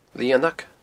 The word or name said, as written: Lianag